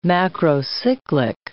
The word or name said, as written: macrocyclic